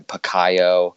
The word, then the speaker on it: Pacquiao: In the beginning of it, they're calling him Pacquiao for the first 10 minutes of it, until Lampley finally gets it all ironed out and realizes that it's Pacquiao.